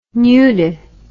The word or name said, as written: nudi